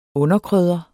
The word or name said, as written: underkrydder